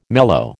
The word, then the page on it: mellow: American English